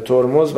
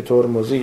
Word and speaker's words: ترمذی: ترمد ضبط کرده ده خدا و گفته ترمد هم میگوین، ولی حال در عربی که رفته ترمز و ترمز شده. حالا ما همون تلفظ فارسی صحیح خودمون رو که ترمذی یا ترمذیست تلفظ میکنیم.